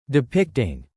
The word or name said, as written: depicting